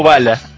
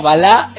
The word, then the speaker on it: Balá: Le dijo Bala. – No, porque le puse el acento en la A. – Ah, porque es muy, muy Bala, te quiso decir. – No, Balá puso el tilde la última.